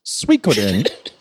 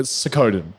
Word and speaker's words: Suikoden: So I now know the game is pronounced Suikoden. But Lockie and I have spent close to 30 years calling it Sokoden.